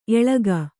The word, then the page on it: ಏಳಕ್ಕಿ: kannada